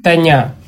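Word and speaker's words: тоня: Давайте, Дмитрий, тогда начнем с того, что вы расскажете, что такое Таня. Правильное ударение? – Да.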